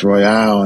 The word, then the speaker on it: Royal: Well, that's a great question, because people would always ask me, you know, your name looks like Royal, but it's pronounced Royale, and it's a meaning behind that.